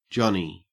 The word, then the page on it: johnny: Australian English